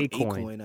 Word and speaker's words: Akoin: It's called Acoin, I think. Yeah. – Acoin? Seriously? – Yeah. Yeah, it's called Acoin, yeah. – What? Spelled Holy shit. It's spelled A-K-O-I-N. – Yeah. A-K-O-I-N? – Yeah.